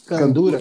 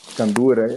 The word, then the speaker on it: candura: Eu vou citar aqui o exemplo da, da candora, candorra, você me, me corrige se eu estiver falando errado aí, que é aquela vestimenta. Candura. Candura. – Candura.